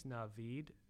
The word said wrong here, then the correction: Naveen: from... – Naveen. – How do you spell it? – N-A-V-E-E-N.